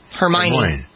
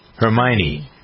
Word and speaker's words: Hermione: There are people who are criticizing her because they don't like the fact that there's a black person playing Hermione. Is that how you pronounce the name? – Hermione. – Hermione.